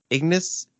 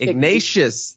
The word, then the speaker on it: Ignatius: That is Saint Ignatius. No, that's not how you say it. Saint Ignatius. – Ignatius. Thank you.